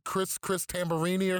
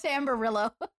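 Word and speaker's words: Tamburello: What do you call him? Chris Tambarini or some shit like that? – It's Tamborillo.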